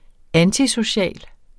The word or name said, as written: antisocial